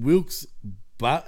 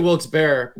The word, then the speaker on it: Wilkes-Barre: But Monday Night Raw comes waltzing back into our lives from the Mohegan Sun Arena at the Casey Plaza in Wilkes-Barre. How do you pronounce that? – I say Wilkes-Barre, but I think it's Wilkes-Barre.